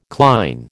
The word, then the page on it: cline: American English